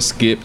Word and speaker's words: Skypp: Well, I go by the name of Skip, and that's spelled S-K-Y-P-P.